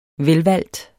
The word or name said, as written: velvalgt